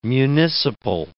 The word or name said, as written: municipal